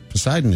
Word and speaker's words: Poseidon: Poseidon is correct.